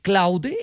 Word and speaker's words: Claude: Claude, exactamente, esa.